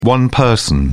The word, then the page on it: one-person: British English